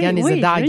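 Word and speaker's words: Kanesatake: Tu le prononces comme il faut. – Ben, c'est Ganizadag. – Tu dis Gani. Oui, Ganizadag. – Oui, c'est bien.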